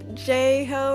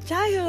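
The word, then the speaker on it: Jai Ho: But Dad and I were having this weird argument about how to pronounce the title of the song and whether or not, you know, it was J-Ho or Jai-Ho. And I was like, it's Jai-Ho, that's how you say it.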